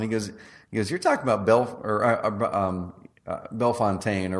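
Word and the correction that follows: Bellefontaine: or whatever it was. Yeah. He said, you're talking about Belle Fountain, right? I'm like, I don't think so, dude. That's not how it's spelled. And he goes, no, it's Belle Fountain.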